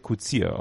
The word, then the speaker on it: Coetzee: Kutzier, als Coetzee wird Kutzier ausgesprochen.